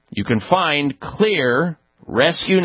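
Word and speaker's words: Xlear: Clear Rescue Nasal Spray. And Clear is spelled with an X, X-L-E-A-R. The X is one of the components in there, xylitol.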